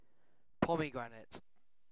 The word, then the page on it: pomegranate: British English